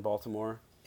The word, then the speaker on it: Baltimore: If you want to pronounce it the way that we do in Baltimore, it's actually pronounced Baltimore. – Baltimore. – Baltimore. – Like Voldemort. – Not like Voldemort. Baltimore. Okay? Take the T, turn it into a D.